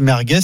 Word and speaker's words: merguez: De débattre, quand même, en l'écoutant, je me suis rendu compte, merguez, il y a encore une histoire de Z prononcé S comme dans Michelin.